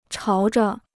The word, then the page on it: 朝着: cháo zhe